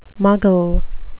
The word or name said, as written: muggle